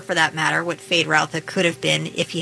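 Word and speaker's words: Feyd-Rautha: Fade Rautha.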